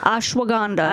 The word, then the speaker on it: ashwagandha: So yeah, they got the chewable, well, the gummies, I never know how to say this word, the ashwagandha. – Ashwagandha. – Ashwagandha, yes. Ashwagandha. And that's for your immune support, too.